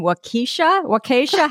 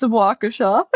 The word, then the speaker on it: Waukesha: Currently lives with her fiance and two dogs in Waukesha. How do you say that? – It's Waukesha. – Oh, I really butchered it.